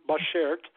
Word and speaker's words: Bashert: It is pronounced Bashert, B-A-S-H-E-R-T, Bashert. It's a Yiddish word, and it means soulmate.